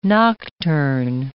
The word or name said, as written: nocturn